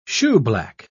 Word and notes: shoeblack: British English

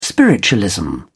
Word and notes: spiritualism: British English

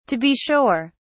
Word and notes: to be sure: British English

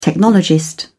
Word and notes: technologist: British English

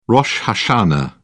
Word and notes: rosh hashanah: British English

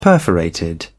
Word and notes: perforated: British English

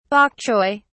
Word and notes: bok choy: British English

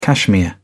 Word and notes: cashmere: British English

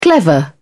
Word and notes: clever: British English

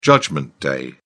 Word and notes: judgment day: British English

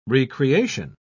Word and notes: re-creation: British English

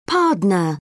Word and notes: pardner: British English

